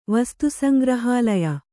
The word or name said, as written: ವಸ್ತುಸಂಗ್ರಹಾಲಯ